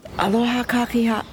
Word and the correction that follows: aloha kakahiaka: Gotta pronounce it correctly. Aloha kakahiaka.